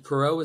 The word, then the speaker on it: Porot: I have Instagram @CMPorot, and Porot is spelled Porot. You are welcome to pronounce it in the French or the English way.